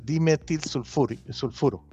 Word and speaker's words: dimetilsulfuro: Pero hay una pequeña incidencia de un poquito se ve, ahí se identifica el espectro de una molécula que se llama eh, dimeltisulfuro. a ver si lo digo bien. – DMS en, inglés, la, dimetilsulfuro.